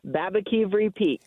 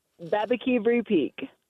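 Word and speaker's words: Baboquivari Peak: Babakivri Peak.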